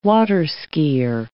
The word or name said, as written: water-skier